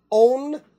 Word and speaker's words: On: We went and saw they're called the Own Ensemble, and it's spelled O-N.